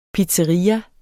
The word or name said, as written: pizzeria